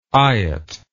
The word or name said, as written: eyot